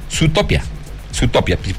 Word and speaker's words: Zootopia: Zootopia, porque la doble O en Estados Unidos se pronuncia U, no se te haga fácil.